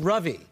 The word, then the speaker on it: Ravi: Okay, so, you know, your name is spelled R-A-V-I, and I just learned it's pronounced Ruvi, not Ravi. – No, well, you mislearned. It was actually... – I fucked up everybody. – Yeah. – Not Ruvi? – It's pronounced Ruvi. – Ruvi, right.